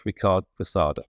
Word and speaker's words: Ricard Quesada: Yeah, so it's Ricard Quesada, but nobody pronounces it correctly.